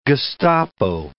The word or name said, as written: Gestapo